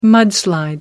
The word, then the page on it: mudslide: American English